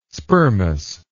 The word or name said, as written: spermous